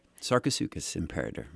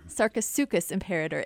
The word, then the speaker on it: Sarcosuchus imperator: Sarcosuchus imperator. – Sarcosuchus imperator.